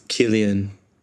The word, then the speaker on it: Cillian: I'm pretty sure it's Killian.